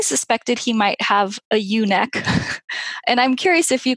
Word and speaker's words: ewe-neck: A ewe-neck, and it's spelled E-W-E like a sheep, because all sheep have ewe-neck.